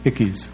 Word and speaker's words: Ickes: Ickes. – That's the Western pronunciation.